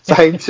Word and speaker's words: Thames: And again, likewise, I think we lost Thames... Or Thames, sorry. – Thames. – Thames, should say it properly, not the English way.